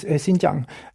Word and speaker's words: Xinjiang: Så vad är det som pågår i Xinjiang? Förlåt mitt uttal. Det kanske är dåligt. – Xinjiang.